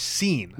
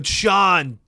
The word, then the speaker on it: Sean: and I read it as seen. Yeah. I'm like, I'd, I'd never heard of it. I'd never seen Sean spelled like that.